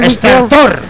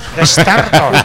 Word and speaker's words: Estertor: Estertor, con acento en la última R. – A mí me da que sí, pero igual es Estertor, no lo sé. – ¿dónde va el acento? – Estertor. Estertor.